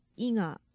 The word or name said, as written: iga